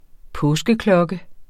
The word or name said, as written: Helleborus